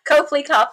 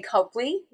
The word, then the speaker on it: Copley: Copley, either one. – Oh, okay. All right. – if you're in the Boston area, people call it Copley Square. Some people say Copley. So either one, it's same spelling.